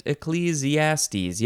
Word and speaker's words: Ecclesiastes: The next word is ECCLES, capital E-C-C-L-E-S. It's another abbreviation for Ecclesiastes. Is that how you say it? Ecclesiastes. Yep, that's it.